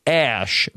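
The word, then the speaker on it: X Æ A-12: Elon Musk has named his son there's some characters here, and I think there's some confusion about how to I think it's pronounced Ash, but or that A. The son's name is X. The letters AE connected A-12.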